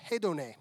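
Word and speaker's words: hedone (ἡδονή): The word here in the Greek means is pronounced hedone. Hedone, which is where we get the word hedon or hedonism.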